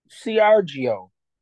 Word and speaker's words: Seargeoh: Sergio. Now, one would think S-E-R-G-I-O. – Sergio, yeah. – But this is S-E-A-R-G-E-O. H. – Sergio. Huh? – Right. Sergio. Yeah.